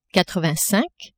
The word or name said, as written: quatre-vingt-cinq